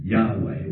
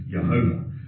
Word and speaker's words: Yahweh: the word that's sometimes pronounced as Yahweh or Jehovah.